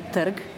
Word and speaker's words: trg: Par exemple, le mot la place, qu'on dit terg, donc T-R-G, sans savoir qu'il y a un E muet à insérer devant le R.